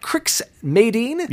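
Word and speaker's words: Crix Madine: We're going to be talking about Crix Mayddin. – Yep. – Did I say it right? – You did. You nailed it.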